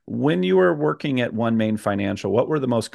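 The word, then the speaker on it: OneMain: And I want to start by talking, is it called One Main Financial or is it pronounced differently? – No, it's called One Main.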